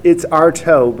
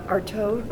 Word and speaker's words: Arthaud: It's Arthaud, but it's spelled A-R-T-H-A-U-D. It's French. – Arthaud? – Arthaud is how a French person would say it, but I don't, it doesn't matter.